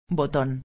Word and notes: boton: espanhol

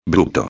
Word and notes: bruto: espanhol

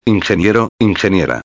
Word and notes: ingeniero/a: espanhol

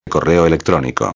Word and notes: correo electronico: espanhol

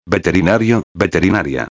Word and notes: veterinario-a: espanhol

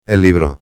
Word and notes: el libro: espanhol

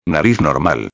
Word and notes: normal: espanhol

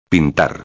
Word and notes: pintar: espanhol

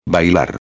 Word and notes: bailar: espanhol

